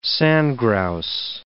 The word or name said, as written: sandgrouse